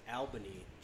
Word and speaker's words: Albany: Did I pronounce it right? No, you didn't. – No. – It's Albany. – That's right. If you say it wrong, they'll throw you out of town.